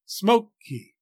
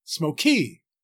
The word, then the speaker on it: smoky: In the word smoky, for example, English speakers place a heavier weight or stress on the first syllable, smoke, than on the second, e, smoky. We don't say smoky.